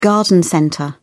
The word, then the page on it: garden centre: British English